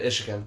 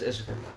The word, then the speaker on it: Michigan: You said Michigan. – Michigan. Yeah, we don't fucking pronounce the M.